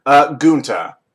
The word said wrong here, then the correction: Gantu: I pronounced it wrong, but I did write it down correctly. – Okay. No, it's Gantu. His name it is Gantu.